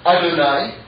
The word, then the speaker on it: Adonai: The other name that we want to focus in on is Aleph Daled Nun and Nun. Alright? Now that's pronounced Adonai, but it's also spelled the same way.